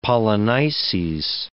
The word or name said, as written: Polynices